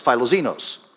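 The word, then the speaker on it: philoxenos (φιλόξενος): It's two Greek words, philo and xenos. And that's how you say it in the Greek. That particular word, hospitality, is philoxenos, which basically means love strangers.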